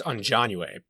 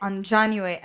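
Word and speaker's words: Aunjanue: And we have, how do I pronounce her name? – I believe it's Anjanue, but I could be wrong. – Anjanue Ellis for King Richard.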